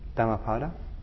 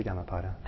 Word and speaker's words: Dhammapada: So my understanding is Dhammapada, but it has to be Dhammapada. Because it's two words, it's Dhamma and Pada, and so you would pronounce it Dhamma Pada. I don't think the compound changes that, the way the syllables are broken up.